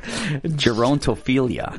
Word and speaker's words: Gerontophilia: Gerontophilia. – Gerontophilia.